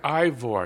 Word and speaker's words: Ivor: Can you explain to our listeners how the co-author of your book, Ivor, was it pronounced? – Ivor. – Ivor. I keep saying that.